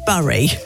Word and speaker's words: Bury: B-U-R-Y. Have I said that right, Ben? – Yes, you have. – Bury.